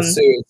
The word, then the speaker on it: Hsu: Sue is spelled H-S-U. Is that correct? – Right. Yeah, ANNE.HSU.